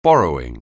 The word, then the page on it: borrowing: anglais